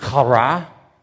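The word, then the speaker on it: chara: This word joy, it's pronounced kara.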